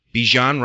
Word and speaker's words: Bijan: Bijan Robinson? Is that how you say it? – Yep.